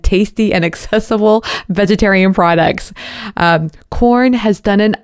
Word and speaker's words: Quorn: First of all, Korn has a vegan veggie burger. Have you heard of Korn? It's spelled Q-U-O-R-N.